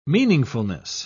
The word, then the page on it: meaningfulness: British English